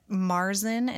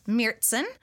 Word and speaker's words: Märzen: I actually, through my beer class, learned that Märzen is not the correct pronunciation. – Oh. – It's Märzen. It's got the two dots over the A.